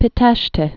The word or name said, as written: Pitesti